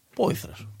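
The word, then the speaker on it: Poythress: Έχουν πάρει αυτόν τον τύπο τη Γαλατά Σάρα που πραγματικά δεν ξέρω πώ να προφέρω το επώνυμό του. Τον Άλεξ Ποηθρέ. Ποηθρέ. – Πόηθρα. – Πόηθρα. Πόηθρα μα τον είπαν, Πόηθρα σα τον λέμε.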